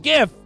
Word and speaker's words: GIF: But I was doing all the jokes about Czechoslovakia, and I had a guy, he sent me the Wild and Crazy Guys GIF from Saturday Night Live. He sent me the, that's how I say it, by the way, hard G, GIF, the graphics interchange format. It's not a giraffics interchange, but, you know, whatever.